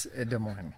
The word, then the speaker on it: Des Moines: The S is silent. It's Des Moines.